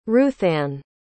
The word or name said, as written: ruthann